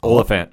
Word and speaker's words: Oliphant: So the gentleman in question was a gentleman by the name of Cobb Vanth, V-A-N-T-H, played by the one and only Timothy, how do you say this? – Oliphant.